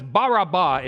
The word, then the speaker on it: Barabbas: Because Barabbas, Baraba is how you say it.